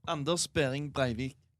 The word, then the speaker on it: Anders Behring Breivik: Anders Behring Breivik.